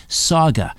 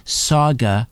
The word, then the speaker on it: Sauga: Saga, that's spelled S-A-U-G-A, as in Mississauga. Saga, Saga, S-A-U-G-A, saga960am.ca.